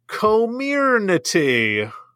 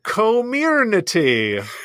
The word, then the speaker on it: Comirnaty: The Pfizer vaccine's official marketed name is Comirnaty. Co, you know, the two syllables you love next to each other, co and mir. Comirnaty. – Comirnaty? – Comirnaty.